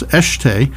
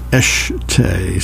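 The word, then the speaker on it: Escheté: It's Eshte, but it's spelled E-S-C-H-E-T-E. And I was pronouncing it Ron Eshte. Because what did I know? Anyway, some listener called me up to chastise me and tell me that I was mispronouncing it. It was pronounced Eshte.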